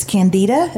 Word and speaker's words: candida: I know that one of the things that my doctor has told me and a lot of women deal with is candida.